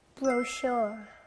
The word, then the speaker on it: brochure: Brochure. – 네, 발음이 너무 좋았어요. sh 소리 나는 brochure.